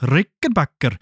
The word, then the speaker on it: Rickenbacker: Dann hat er sich ein paar Geldgeber gesucht, nämlich den Schweizer Adolf Rickenbacker. Oder Rickenbacker. So, richtig ausgesprochen. Adolf Rickenbacker.